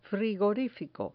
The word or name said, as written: frigorifico